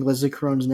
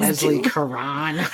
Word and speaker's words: Leslie Caron: And are you proud of me for pronouncing Leslie Caron's name correctly? – Instead of making it super fancy like you always do. Leslie Caron.